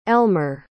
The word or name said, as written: Elmer